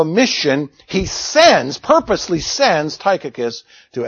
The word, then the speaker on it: Tychicus: Or Tychicus, I think is probably the better way to pronounce it.